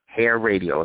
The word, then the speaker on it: hairradio: Hair Radio is how it's pronounced.